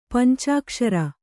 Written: ಪಂಚಾಕ್ಷರಿ

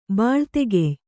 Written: ಬಾಳ್ತೆಗೆಯ್

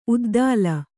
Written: ಉದ್ದಾಲ

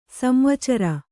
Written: ಸಂವಚ್ಚರ

